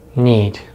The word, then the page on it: need: American English